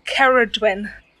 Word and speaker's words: Ceridwen: It's pronounced Ceridwen.